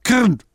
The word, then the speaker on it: Krn: Es gibt im Tschechischen, man müsste ein Zungen-R können. Die Deutschen können das nicht aussprechen. Ich buchstabiere mal. K-R-N. Kirn. Ja, etwa in der Richtung.